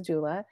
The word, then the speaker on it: doula: How you began as a doula, and please tell me if I'm pronouncing that wrong. Would you tell me about how you came into that career? – Yeah, and you are saying it right. It's a doula.